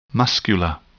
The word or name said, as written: muscular